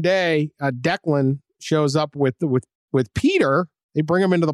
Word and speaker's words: Peadar: This is how Irish it is. It's spelled P-E-A-D-A-R. – Oh, all right. – Right? That is Irish. That's very Gaelic. Peter Doyle, 66, and he has a nephew, Declan Haughey, or Haughey.